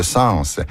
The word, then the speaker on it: sens: En revanche, dans l'expression « ça n'a pas de bon sens », il est recommandé de prononcer l'S final de « sens ».